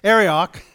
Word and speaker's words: Arioch: I listened to the Bible, audio Bible, so I know how to pronounce this guy's name. Arioch, was the king's main man to go round up all the wise men and kill them all.